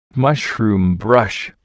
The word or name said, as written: mushroom brush